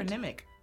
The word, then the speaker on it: patronymic: Patronymic. – That's it. – We don't know. – It is it. It's patronymic. – Patronymic. – Patronymic. – Patronymic. – Patronymic. – Sneaky motherfucker. Short A.